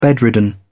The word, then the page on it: bedridden: British English